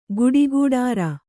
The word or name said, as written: ಕಾಡಕಠಾರಿ